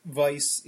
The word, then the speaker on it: Weiss: Weiss is the German word for white, and it is a style of beer. And, you know, in Germany they pronounce their W's as V's, so.